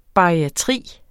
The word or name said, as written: bariatri